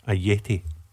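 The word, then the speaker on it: Ajeti: And I'm pronouncing it Ayeti because some of our listeners have gone onto social media saying they don't want me getting involved in another Big Chris debacle.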